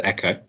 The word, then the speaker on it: Exo: Newcomers to the field, such as Butterfly Network and EXO, pronounced Echo, are struggling to unseat the incumbent market leaders.